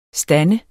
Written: stod

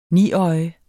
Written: niøje

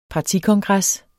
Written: partikongres

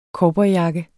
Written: COWBOYJAKKER

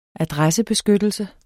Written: adressebeskyttelse

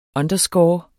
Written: underscore

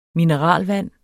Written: MINERALVANDERS

